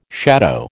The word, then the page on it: shadow: American English